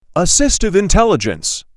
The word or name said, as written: Assistive intelligence